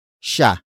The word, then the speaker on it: siya: The next word is sha. Sha. Sha. Sha is spelled as S I Y A.